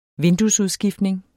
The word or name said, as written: vinduesudskiftning